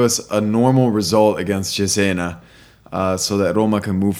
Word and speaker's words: Cesena: It's not Cessna. – Hey, after the Cagliari debacle last week, is hedging his bets. – That's right. That's right. There's three ways to say that word, and you're saying it right.